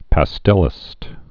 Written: pastelist

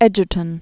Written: Edgerton

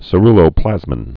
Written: ceruloplasmin

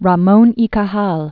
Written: Ramon y Cajal, Santiago

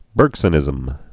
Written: Bergsonian

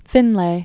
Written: Finlay

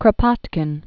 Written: Pyotr Alekseyevich Kropotkin

